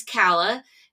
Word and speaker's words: Calah: And I said, Calah, which a lot of people have gotten that wrong. They've said Calah or Kayla, or I've heard all kinds of things, but it's Calah.